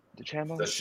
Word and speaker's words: DeChambeau: How do you say DeChambeau? – DeChambeau.